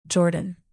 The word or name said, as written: jordan